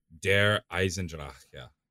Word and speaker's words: Der Eisendrache: But to those who want to hear it, Der Eisendrache. That's how I say it.